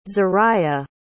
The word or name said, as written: zariyah